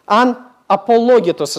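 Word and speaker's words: Анапологетос (ἀναπολόγητος): Это слово по-гречески звучит «анапологетос». «Анапологетос», извиняюсь, ударение. «Анапологетос».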